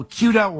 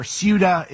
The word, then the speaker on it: Ceuta: So Ceuta or Ceuta, is I've heard it pronounced both ways, is a part of Spain that is actually on the northwesternmost part of Africa.